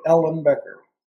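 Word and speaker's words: Ellenbecker: But yeah, it sounds just like it's printed. Ellen Becker.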